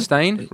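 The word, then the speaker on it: Stayn: Now, is it pronounced Stan or Stain? – Stain, isn't it? – Stain? Right, okay. Stain. Yeah. It's S-T-A-Y-N, I think.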